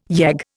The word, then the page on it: yegg: Indian